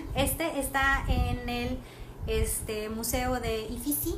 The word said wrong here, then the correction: Uffizi: Siempre lo digo mal porque es Rix, Ifisi. déjame se los digo bien. Uffizi, U FF y ZI, Fisi, en Florencia.